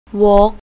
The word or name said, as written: vok